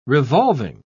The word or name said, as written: revolving